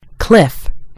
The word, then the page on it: cliff: American English